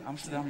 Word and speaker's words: Amsterdam: Ik weet nog dat ik in Amsterdam was. – Did I say that right? – Ja, Amsterdam, ja, very good.